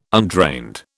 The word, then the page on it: undrained: British English